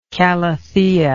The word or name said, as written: calathea